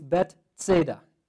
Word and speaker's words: Bethsaida: In the Hebrew, it's Bethsaida. Bethsaida. And the other one, Bethesda, is Bethesda. So Bethesda and Bethsaida.